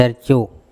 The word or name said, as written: Erco